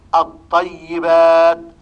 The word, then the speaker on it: الطيبات: فعندما تنظر إلى اللام في نحو الطيبات تجد أن اللام ليس عليها علامة السكون وأن الطاء مشددة، فهذا هو دليل الإدغام. التشديد يدل على الإدغام، والنطق يكون بطاء مشددة. الطيبات الثواب، النطق هنا بثاء مشددة.